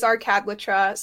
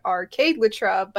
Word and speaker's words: Arcadletra: So yes, it is Arcadletra. Some people may feel like they want to pronounce it Arcadelatra, but it is Arcadletra.